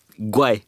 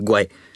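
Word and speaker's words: Гуай (怪): Любимата ти дума на китайски е Гуай. – Какво означава? – Странно, причудливо, извратено. Може ли да я кажеш пак? Гуай. И трябва да се произнесе по този начин. Гуай.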